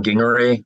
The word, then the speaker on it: Gingery: Yeah, my name is Adam Gingery with a hard G's, not soft G's like gingery.